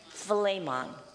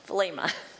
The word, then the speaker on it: Philemon: How do you say it? Philemon. Okay, like a steak. Philemon.